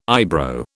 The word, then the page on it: eyebrow: British English